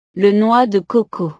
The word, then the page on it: le noix de coco: frances